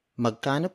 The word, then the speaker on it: magkano: When you see an item that you like and wonder how much it cost, you ask, Magkano po ito? Magkano po ito?